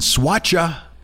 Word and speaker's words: Soacha: It takes place in Soacha, Colombia. What a great name. It's spelled S O A C H A, Soacha. Soacha, Soacha, Colombia.